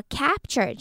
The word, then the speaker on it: capture: 一样的发音。然后看到这边的 kind of 这边要连起来，D 结尾，O 开头，kind of，然后这边的 F 呢，其实不需要发出气音，只要轻轻咬一下嘴唇就可以带到下一个字，kind of trying to，然后看到 capture 这个单字，capture 它的 A 发的是 a 蝴蝶音，嘴巴要打开往旁边张，然后舌头往下压 a。Capture, 然后 capture 的 T-U-R-E 的发音呢和 teacher 的 ch